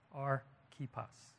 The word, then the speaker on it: Archippus: And in closing, he mentions one more, Archippus. That's how it's pronounced. Archippus.